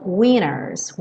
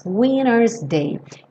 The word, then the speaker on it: winter's: Eu pintei esse T de cinza como uma letra não pronunciada porque na gravação original eles falam winners, winner's day. É um sotaque bem americano de reduzir tanto o T que ele some.